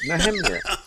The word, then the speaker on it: Nehemiah: Well, it's the correct way to say it, Nehemiah.